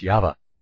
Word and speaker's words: Yahweh: The Tetragrammaton and proper Antioch Hebrew scrolls spells YHVH, pronounced Yahweh.